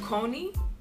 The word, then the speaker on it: Connie: My name is pronounced Coney, but it has been Americanized to Connie since birth from my family. So I actually go by both ways because I love both ways and my family uses both ways.